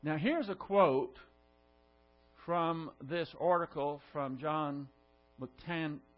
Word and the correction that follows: McTernan: How do you say this again? McTernan.